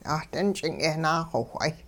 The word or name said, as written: 'aht'ing-ch'ing yehnaaxowh'ay